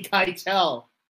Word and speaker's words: Keitel: It's pronounced Harvey Keitel.